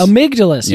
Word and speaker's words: Amygdalus: Amygdalus.